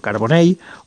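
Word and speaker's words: Carbonell: Y, bueno, pues eso, que en valenciano eh, eso se pronuncia como una L o como una Y, si queréis, eh, y se diría pues eso, carbonei, o sabadei, o martorei o no sé, mm, cualquier otra cosa que se os pueda ocurrir.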